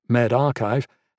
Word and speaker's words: MedRxiv: At the start of the year, one repository, MedRxiv, pronounced MedArchive, held about 1,000 preprints.